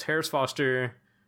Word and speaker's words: Harris Foster: And I told him my name is Harris Foster, HARRISFOSTER.